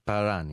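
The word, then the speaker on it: Prahran: Θα δώσουν 8 παραστάσεις από τις 20 έως τις 29 Σεπτεμβρίου σε κάποιον κεντρικό χώρο στην περιοχή Πράραν στην Μελβούρνη. Το λέω σωστά? – Παράν είναι, Παράν. Δεν προφέρεται. Είναι από αυτά τα περίεργα.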